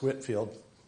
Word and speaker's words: Whitefield: It's spelled Whitefield, but it's pronounced Whitfield.